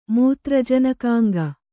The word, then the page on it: ಮೂತ್ರಜನಕಾಂಗ: mūtra janakānga